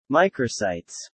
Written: microsites

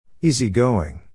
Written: easygoing

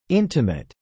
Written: intimate

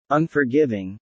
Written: Unforgiving

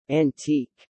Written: antique

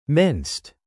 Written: minced

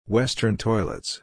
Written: Western toilets

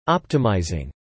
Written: optimizing

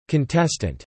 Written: contestant

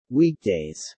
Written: weekdays

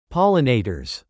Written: pollinators

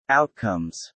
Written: outcomes

